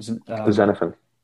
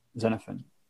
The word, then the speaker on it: Xenophon: Xenophon. – Xenophon.